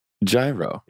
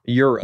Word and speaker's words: gyro: If you order a G-Y-R-O, how do you pronounce it? – Gyro. – Euro. – No. – Euro. – A gyro? What is it? – You're wrong on both counts. – It's a gyro.